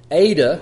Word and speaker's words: eta: So it's alpha, beta, gamma, delta, epsilon, zeta, which is a Z, eta, which is the AY sound,